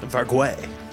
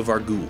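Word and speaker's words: Vargouille: It does have an E at the end, so you should pronounce the L, so Vargul.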